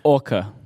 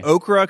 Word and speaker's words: Orca: Orca. – Orca. With I wanted to say okra because I'm from Tennessee, but that's not right.